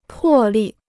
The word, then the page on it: 破例: pò lì